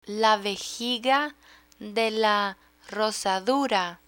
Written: la vejiga de la rozadura

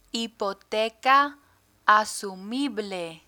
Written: hipoteca asumible

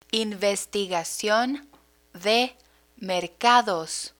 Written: investigacion de mercados

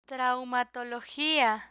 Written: traumatologia

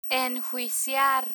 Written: enjuiciar

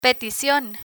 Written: peticion

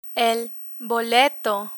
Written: boleto

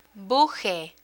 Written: buje

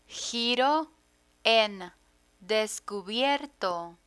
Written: giro en descubierto

